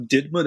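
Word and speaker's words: DIDMCA: So, in 1980, Congress passes a law and it's pronounced sort of DIDMA. That's the shorthand for it.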